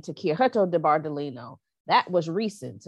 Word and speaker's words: Chiaretto: So, but you're Chiaretto. – Saying. – Chiaretto. – Chiaretto. – Yes. – Okay. – C-H-I-A-R-E-T-T-O. – Chiaretto.